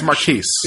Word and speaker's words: Marquise: Moving on, we have Marquise. I'm saying that right? Marquise? Marquise? – It's French. – It's Marquise.